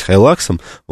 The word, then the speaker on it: Hilux: Ну, они какие-то есть правильные, ты вроде стараешься следовать каким-то правильным вещам, правильным произношениям, поэтому Hilux, я прекрасно понимаю, почему мы так его все называем, я уже переучился называть Хайлаксом, вот, ну, возможно, когда-нибудь и все переучимся.